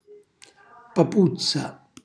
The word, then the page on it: papuzza: calabrese